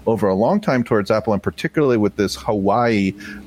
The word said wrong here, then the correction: Huawei: or Highway, I don't know how you pronounce that. – Huawei. – Thank you.